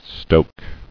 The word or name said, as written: Stoke